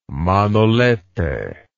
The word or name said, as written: Manolete